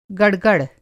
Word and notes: गड़-गड़: گڑگڑ

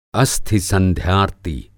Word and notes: अस्थिसंध्यार्त्ति: Hindi

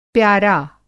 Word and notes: प्यारा: پیارا

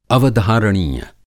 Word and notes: अवधारणीय: Hindi

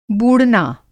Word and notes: बूड़ना: Hindi

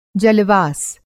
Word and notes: जलवास: Hindi

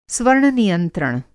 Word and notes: स्वर्ण-नियंत्रण: Hindi

